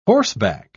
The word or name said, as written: horseback